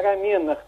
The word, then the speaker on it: рамена: Правильное ударение – рамена.